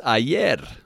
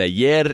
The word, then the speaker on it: ayer: So today's word of the day is ayer, which is yesterday. So yesterday's word was today, and today's word is yesterday. And 2 days ago, the word was tomorrow. So anyhow, today is ayer, and ayer is spelled A-Y-E-R, ayer.